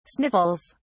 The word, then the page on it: sniffles: English; British English